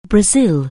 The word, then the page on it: brazil: anglais